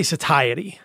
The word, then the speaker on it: satiety: And how do you pronounce that word? – I say satiety.